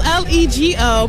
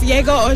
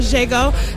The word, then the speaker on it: LLEGO: We're on Facebook, Yego, L L E G O, but it's you know it says Lego, but it's pronounced Yego or Jago, depending on you know how you pronounce it, how you see it.